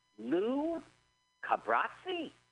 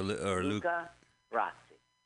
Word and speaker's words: Luca Brasi: So is it Lou Cabrazzi or is it Luca? – I think you're right. It's Luca. Or Luca Brasi.